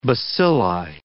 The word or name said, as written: bacilli